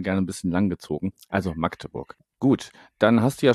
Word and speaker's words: Magdeburg: Ich muss mir jetzt gerade selber, habe ich jetzt festgestellt, ich habe es eben wieder falsch gesagt. Das heißt Magdeburg und nicht mhm.